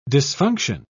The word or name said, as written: dysfunction